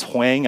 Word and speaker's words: Hwang: It's Hwang, but I was known as Hwang, and I even adopted that last name pronunciation for a long time.